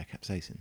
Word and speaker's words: capsaicin: So the thing that, the compound that that you're tasting that kind of hot flavor is a predominantly a compound called capsaicin, right? Mm-hmm. Which is a kind of compound which you is know, that how you say it? – Yeah, capsaicin.